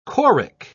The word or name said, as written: choric